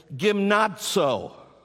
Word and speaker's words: gymnazo: it's pronounced this way, gymnazo. Gymnazo. Do you see it on the screen? Gymnazo. And it's very interesting that the whole first part of that word is like gym.